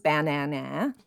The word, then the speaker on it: banana: That includes a schwa sound for the first syllable. – Buh. – Buh. Right, yeah. Right. You could also do it with all the A's saying their sounds. Banana.